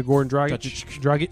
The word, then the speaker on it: Goran Dragic: Yeah, Goran Dragic.